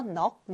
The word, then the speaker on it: 넋: 넋. 넋은 여러분 정신 또는 마음 같은 걸 의미하는데요. 넋은 이렇게 기역. 넋으로 소리가 납니다.